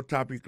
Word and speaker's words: topic: Alors, mot topic là, qui signifie sujet, ça veut dire accent tonique là tombé sous premier syllabe là.